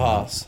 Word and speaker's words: Haas: I can never Haas. Haas. I think it's Haas. – Yeah, it is Haas. – Team America. – Sounds like double S, but it's actually double A.